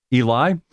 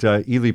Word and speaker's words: Ely: I think maybe a lot of people might know the name, might mispronounce his name like I did, Eli. But Ely Parker lived from 1828 to 1895,